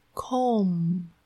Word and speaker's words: comb: 再来 comb c-o-m-b，注意这个 b 是不发音的哦。